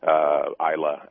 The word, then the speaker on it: Islay: Isla, I-S-L-A-Y, pronounced Isla, and so forth.